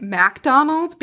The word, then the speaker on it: MacDonald: So I'm pronouncing it MacDonald because it's spelled MacDonald, not like McDonald's where you get your Big Mac.